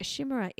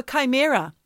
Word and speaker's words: chimera: I did not know how to say it because it's spelled C-H-I-M-E-R-A. – That's right. – I thought it was a shimmera or a chimera. Chimera.